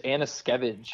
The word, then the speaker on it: Anuszkiewicz: It is Anuszkiewicz. – Anuszkiewicz. I said, okay, Skevich, like Manischewitz or something like that.